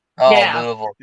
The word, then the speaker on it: Louisville: So, Louisville. – And I said it correctly.